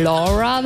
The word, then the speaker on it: Laura: Oder Laura, wenn man es richtig aussprechen möchte.